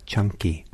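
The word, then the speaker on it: Caṅkī: It's usually C-A-N-K-I. That's the Pali spelling. C-A-N-K-I, but it's pronounced chunky, like with C-H.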